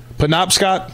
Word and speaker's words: Penobscot: They're in the – is it – how do you pronounce the building? – Penobscot. – Yeah. They're in the Penobscot.